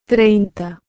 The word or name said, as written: 30